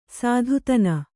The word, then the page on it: ಸೂತತನ: Kannada